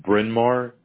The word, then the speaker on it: Bryn Mawr: It's Bryn Mawr, B-R-Y-N space M-A-W-R.